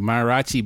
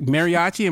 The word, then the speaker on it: mariachi: Like mariachi, am I saying it right? – Yeah, that's it. Mariachi.